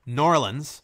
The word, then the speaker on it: New Orleans: But they call all sub sandwiches in New Orleans, or specifically Norlands, which is how they pronounce it there.